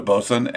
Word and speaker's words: Boatswain: It's spelled Boatswain, but that's like a deck boss on a boat. But you just call it a Boatswain, and the beer looks really good.